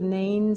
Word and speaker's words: Nain: They walked 25 miles down into the Jezreel Valley to the city of Nain, which is on the southern border of Galilee.